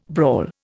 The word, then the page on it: brawl: Indian